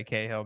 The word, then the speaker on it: Cahill: So how do I pronounce your surname? I've been worrying about this all day. – Oh, I don't know. You know, it's amazing. In the U.S., they still can't spell it right. I've gotten, like, when I get coffee from Starbucks, I've literally gotten, like, the letter K-H-I-L. So we just say Cahill, man.